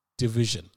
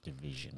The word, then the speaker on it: DVSN: It's spelled DVSN, but it's pronounced Division. – Division.